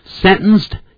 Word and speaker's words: sentenced: He was sentenced to 5 years in prison， sentenced 用用鼻音。